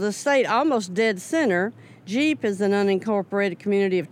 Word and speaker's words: Gepp: How do you pronounce G-E-P-P? – This, too, is close to where I grew up. This is Jeep. – Oh, really? – Yeah. – Uh-huh. Yeah. – Jeep. – Jeep. Yeah. Not Gep, nope.